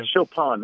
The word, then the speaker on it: Chopin: Oh, yeah, Chopin. That's Chopin. Right. Chopin's the grain. – I'm glad you told me how to say it. Now I know to say it properly, Chopin.